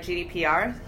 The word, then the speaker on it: GDPR: So technologies around ads.txt, GDRP, did I say that right? GDPR? Wow, second time.